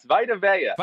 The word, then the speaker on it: Vita Vea: Vita Vea, am I saying that right? Yes, Vita Vea. – Vita Vea. Yes.